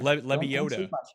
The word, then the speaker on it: Lebioda: He's right by the bod, Hank Leboidia. – Yeah, Lebioda.